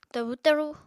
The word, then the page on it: tabu-taru: Apatani